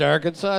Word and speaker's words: Arkansas: Even though Arkansas is pronounced Arkansas, it's like Arkansas.